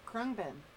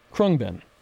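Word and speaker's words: Khruangbin: And we're going to move into August 10th by, how do I say this band name, Aunt Suze? – Krungbin. – Krungbin. – You got it. Nailed it. – Nailed it.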